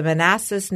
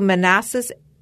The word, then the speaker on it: Manassas: The Manassas Battlefield Trust. And they're a dedicated group that protects the resources of the Manassas National Battlefield Park.